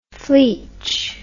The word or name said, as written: fleech